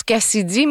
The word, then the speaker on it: KCIDY: Parlons maintenant de l'artiste Cassidy, qui se prononce Cassidy, mais ça s'écrit K-C-I-D-Y.